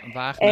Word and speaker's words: Wagnatie: Of ja, Wagnatie, volgens mij spreek je dat zo uit. – Ja, Wagnatie.